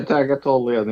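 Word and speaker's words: Tartaglione: Tagatolian? How do you say that? – Yeah, Tagatolian, yeah.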